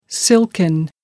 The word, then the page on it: silken: American English